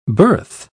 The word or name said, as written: birth